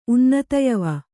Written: ಉನ್ನತಯವ

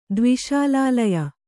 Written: ದ್ವಿಶಾಲಾಲಯ

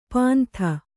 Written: ಪಾಂಥ

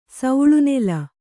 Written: ಸೌಳ್ನೆಲ

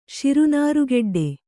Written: ಶಿರುನಾರುಗೆಡ್ಡೆ

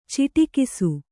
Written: ಚಿಟುಕಿಸು

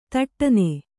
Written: ಥಟ್ಟನೆ

